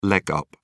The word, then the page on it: leg up: British English